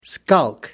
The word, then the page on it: skulk: American English